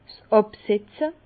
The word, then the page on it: 's Àbsetze: Bas-Rhin; Herrlisheim